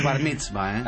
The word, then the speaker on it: bar mitzvah: Barnizba, en serio, hacíamos Barnizba. – ¿Cómo pronuncia Barnizba? – Barnizba. – No, no, Bar mitzvah.